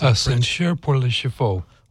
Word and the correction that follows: Ascenseur pour l'échafaud: Ascenseur pour l'échafaud.